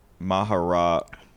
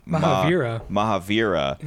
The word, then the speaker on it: Mahavira: Yeah, and it says all these people, including Mahavira, are we saying that right? Mahavira. Mahavira.